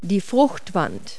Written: rind

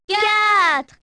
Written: quatre

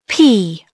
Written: pea

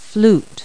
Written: flute

